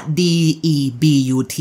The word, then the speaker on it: debut: D E B U T